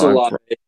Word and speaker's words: Olave: No, it's Olave.